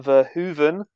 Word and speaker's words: Verhoeven: Not in a normal erotic thriller, you know, coming from Paul Verhoeven.